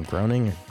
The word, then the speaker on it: Groening: I bet Matt Groening, whatever, how he pronounced his last name, Groening? – Groening.